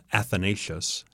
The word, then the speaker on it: Athanasius: And so a fellow by the name of Athanasius, how do you pronounce that? – Yeah, that's right.